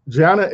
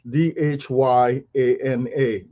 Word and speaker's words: dhyana: Now, dhyana is spelled D H Y A N A, dhyana, which means mindful contemplation.